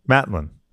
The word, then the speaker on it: Matlin: Matlin, yeah.